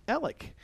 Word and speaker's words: Alex: My first name is spelled like Alex, but it's an old family name that's pronounced Alec.